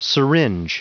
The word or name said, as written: syringe